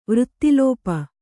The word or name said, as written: ವೃಥಾಳಾಪ